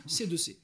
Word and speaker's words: C2C: Quittons Jacqueline et la Marseillaise pour le groupe Si tout Si qui se prononce en bon français C2C.